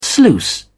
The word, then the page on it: sluice: British English